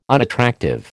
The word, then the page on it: unattractive: American English